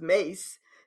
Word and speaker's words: Myss: She pronounces it Carolyn Mace, like M-Y-T-H would be myth, but M-Y-S-S is Mace.